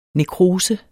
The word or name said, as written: nekrose